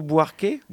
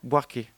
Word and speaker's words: Buarque: On va commencer peut-être avec, qu'est-ce que t'en penses, Chico Buarque ? Buarque, ça se prononce comme ça.